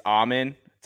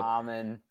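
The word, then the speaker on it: Amen: It's Eamon Thompson. So Eamon. – Get it right before we start our fantasy show and we're drafting him.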